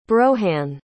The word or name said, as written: broehain